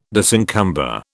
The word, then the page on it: disencumber: British English